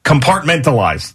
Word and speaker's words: compartmentalized: Carpentalized is another word I can't say. How do you say that? Compartmentalized.